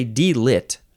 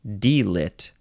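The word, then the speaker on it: D.Litt: Now, I think you would call this, you'd say D-lit. Capital D, capital L, I-T-T, or you can only spell it with one T, which I feel like makes way more sense. D-lit.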